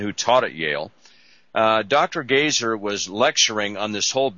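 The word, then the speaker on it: Goeser: Gaiser, no longer living.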